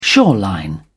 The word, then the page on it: shoreline: British English